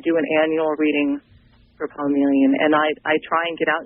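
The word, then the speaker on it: Poemeleon: It's pronounced Poemelian, like poem and chameleon smashed together.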